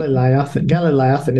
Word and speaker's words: Galileothen: So tell me a little bit more about is it the Galilean? Am I saying that right? – Galileothen. It's too much of a mouthful.